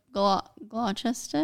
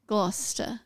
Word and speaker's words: Gloucester: So there were some events by the Duke of Gloucester. Is that how you say it? – Have another go. – Gloucester. – There you go.